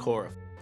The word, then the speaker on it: Okorafor: Okorafor.